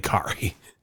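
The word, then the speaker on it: Khari: I don't think you said that correctly. – Well, how do you say his name? – I think it's Blossing Game. And I don't know, maybe it's Carrie. – It's Kari. – I don't know. – It's definitely Kari.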